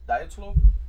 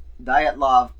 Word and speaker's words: Dyatlov: Diatlov. – It's pronounced Diatlov past incident. – Diatlov. There we go. Thank you, sir.